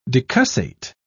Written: decussate

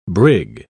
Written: brig

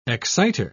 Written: exciter

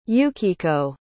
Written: Yukiko

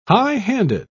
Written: high-handed

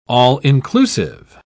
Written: all-inclusive

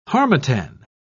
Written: harmattan

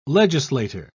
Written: legislator

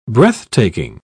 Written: breathtaking

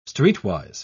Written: streetwise

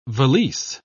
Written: valise

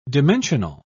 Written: dimensional

